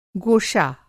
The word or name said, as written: गोशा